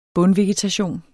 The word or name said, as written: bundvegetation